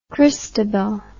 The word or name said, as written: christabel